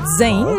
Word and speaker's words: Jain: Jane? – Jane, en fait, on le prononce comme ça, c'est Jane. J-A-I-N, mais on le prononce comme s'il y avait un Z, donc Jane.